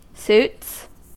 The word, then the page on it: suits: American English